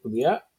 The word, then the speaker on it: Oubliette: Oubliette, is that right? Oubliette? – Yeah, you nailed it.